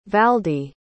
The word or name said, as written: valde